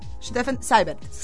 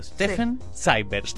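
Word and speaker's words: Steffen Seibert: Stefan Seibert. E-I en alemán es I. – Ok, A-I. Así vamos aprendiendo. Stefan Seibert.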